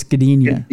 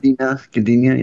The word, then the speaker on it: Gdynia: Gdynia. I believe it's Gdynia. – Gdynia, yeah.